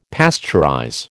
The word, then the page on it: pasteurize: American English